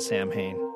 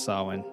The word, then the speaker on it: Samhain: When I was a kid, my friends all pronounced it Samhain. They didn't speak Gaelic, so they didn't know it's pronounced Samhain.